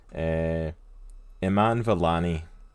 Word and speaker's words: Iman Vellani: Iman Vellani, who is the person that's playing Kamala Khan.